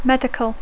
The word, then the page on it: medical: English